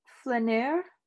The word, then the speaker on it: flâneur: It's flâneur.